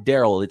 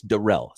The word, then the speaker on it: Darrell: It's not Daryl, it's Darrell. So Darrell Luter is is gotcha. the, the the proper pronunciation there.